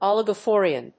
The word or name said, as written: oligophrenia